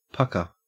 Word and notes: pukka: Australian English

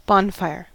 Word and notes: bonfire: American English

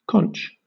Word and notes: conch: British English

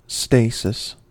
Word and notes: stasis: American English

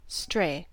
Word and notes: stray: American English

